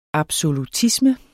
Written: absolutisme

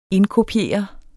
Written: indkopiere

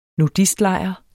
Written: nudistlejr

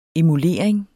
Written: emulering

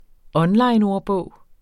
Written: onlineordbog

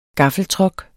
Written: GAFLEN

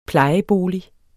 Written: plejebolig

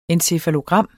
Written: encefalogram

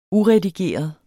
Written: uredigeret

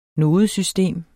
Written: NODESYSTEM

